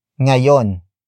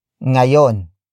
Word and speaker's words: ngayon: For example, Mainit ngayon sa labas ano? Mainit ngayon sa labas ano? Mainit ngayon sa labas ano? It means, it is hot outside today, right? Word for word it's, mainit. Ngayon.